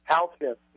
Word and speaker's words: Halcyon: Halcyon, yeah.